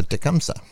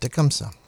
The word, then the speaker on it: Tecumseh: All right, so Tecumseh. That's how you pronounce that, Tecumseh.